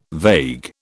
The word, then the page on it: vague: British English